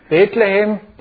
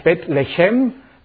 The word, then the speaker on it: Bethlehem: Bethlehem, da so spricht man es Hebräisch, so sieht das aus. Heißt eigentlich Bethlehem.